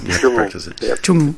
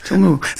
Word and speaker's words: Tjungu: Tjungu. – Tjungu. So the N and the G and the U is a ngu sound.